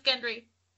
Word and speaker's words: Gendry: It's pronounced Gendry. Sorry. That's okay, crazy. – In America, we're going to call him Gendry.